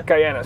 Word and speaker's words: Guyana: Is there a hierarchy to being having your parents from, how do you pronounce it? – Guyana.